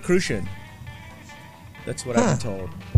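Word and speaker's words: Cruzan: It's pronounced Crucian. – It's not Crouzon? – Not Crouzon.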